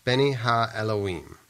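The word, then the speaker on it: Beni Ha Elohim (בני האלהים): In the Hebrew, the expression sons of God is pronounced Beni Ha Elohim. These Hebrew words consist of 9 characters, and the numeric equivalent adds up to 153.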